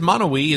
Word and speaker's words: Monowi: Oh, it's spelled M-O-N-O-W-I, okay. – Monowi.